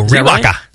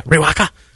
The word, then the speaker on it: Riwaka: For example, Rakow, I don't know if I'm pronouncing that right, or Riwaka Sauvin. – Oh, Riwaka. – Yeah, Riwaka.